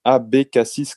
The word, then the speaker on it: ABK6: And this brand is Abbé Cassis Cognac. So if we pronounce it in English, then it will be ABK6 Cognac. Mm-hmm. So he's using, yeah, the three letters ABK and number six.